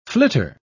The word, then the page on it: flitter: British English